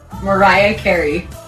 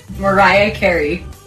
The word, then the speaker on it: Mariah Carey: Doncs com s'ha de pronunciar? S'ha acabat el misteri. Ho haurien de pronunciar així. – Mariah Carey. – Tornem-hi. – Mariah Carey.